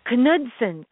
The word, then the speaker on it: Knudsen: And by the way, it's Dr. Joyce, Joyce, Knudsen, Knudsen.